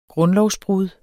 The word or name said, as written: grundlovsbrud